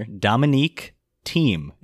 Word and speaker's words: Dominic Thiem: His name was, I've written it, the spelling down here, Dominic Thiem is how you say it.